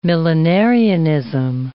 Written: millenarianism

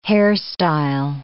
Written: hairstyle

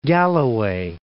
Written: Dumfries and Galloway

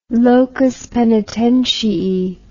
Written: locus poenitentiae

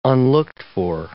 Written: unlooked-for

